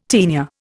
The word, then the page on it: taenia: Indian